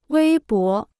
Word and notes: 微博: wēi bó

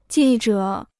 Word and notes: 记者: jì zhě

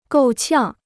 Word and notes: 够呛: gòu qiàng

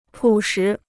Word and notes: 朴实: pǔ shí

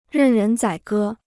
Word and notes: 任人宰割: rèn rén zǎi gē

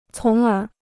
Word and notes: 从而: cóng ér

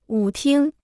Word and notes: 舞厅: wǔ tīng

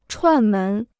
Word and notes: 串门: chuàn mén

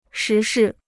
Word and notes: 时事: shí shì

